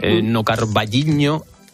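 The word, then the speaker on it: O Carballiño: Nocarballiño. Lo he dicho bien, Paula, bien, sí.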